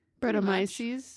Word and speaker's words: Brettanomyces: and then re-fermented on natural blackberries with Brettanomyces. – Oh, yeah. – Did I say it right? – Brettomyces.